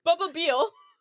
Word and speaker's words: Bubeel: How do you say your character's name again? Buble? What? – It's Michael Buble. – Bubba Beal. Oh. But his first name is Michael. Michael Bubba Beal.